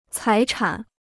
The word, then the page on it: 财产: cái chǎn